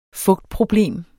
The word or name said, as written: fugtproblem